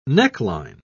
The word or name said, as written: neckline